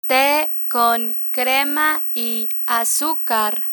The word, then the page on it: te con crema y azucar: spanish